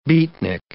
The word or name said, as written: beatnik